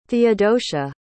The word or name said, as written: theodosia